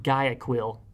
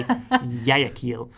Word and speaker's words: Guayaquil: It looks like Guayaquil, but the locals say Guayaquil.